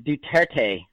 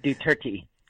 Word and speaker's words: Duarte: And it's pronounced Duterte. – Duterte. – It's like do 30, but without the H. Duterte. – Duterte. – So they're very close. Yeah, there you go. Perfect. At least that's how I pronounce it.